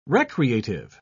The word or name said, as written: recreative